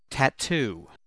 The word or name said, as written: tatoo